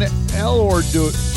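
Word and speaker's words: Elorduy: And an A and a B side to a single, Julian Elordui. I can't pronounce his last name. It's E-L-O-R-D-U-Y. Elordui. Radio Memphis.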